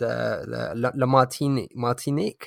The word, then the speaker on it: Martinique: And as you said, it's called Inner Journey, and it charts your kind of return to, as you said, La Martinique.